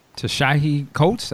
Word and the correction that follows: Ta-Nehisi Coates: I don't know if I'm saying his name right. I apologize. – But Ta-Nehisi Coates. – Ta-Nehisi Coates.